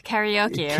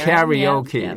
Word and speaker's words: karaoke: It's usually karaoke. – Karaoke, right? Karaoke.